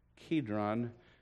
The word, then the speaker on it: Kidron: Kidron, we always pronounce that with a hard C, if you will.